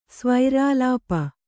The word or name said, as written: ಸ್ವೈರಾಲಾಪ